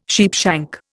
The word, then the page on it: sheepshank: Indian